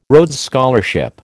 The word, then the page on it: Rhodes Scholarship: American English